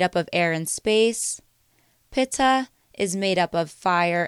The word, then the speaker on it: Pitta: Vata, which is spelled V-A-T-A, Pitta, P-I-T-T-A, and Kapha, K-A-P-H-A. Vata, Pitta, and Kapha.